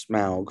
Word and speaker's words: Smaug: All right, welcome back, nerds and nerdettes, and we are talking about The Hobbit, The Desolation of Smaug, or Smaug, depending on how you pronounce it, but... – Smaug. – Yes, most likely it's Smaug from the A-U.